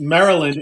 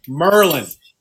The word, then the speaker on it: Maryland: Good morning, and for those of you who live in Baltimore, it is now not pronounced Maryland; it's pronounced Merlin.